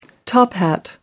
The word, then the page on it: top-hat: American English